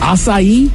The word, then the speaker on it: acai: Acai? That's how you say that? I've been calling them Aki bowls.